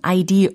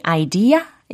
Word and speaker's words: idea: 자, 여기에서 혹시 내가 지금 idea 이렇게 r 사운드로 끝부분을 발음하시는 분이 계실지 모르겠는데 그렇게 하면 안 됩니다. Did you have any idea? 이렇게 좀 가볍게, 담백하게 발음을 해주시면 좋겠어요.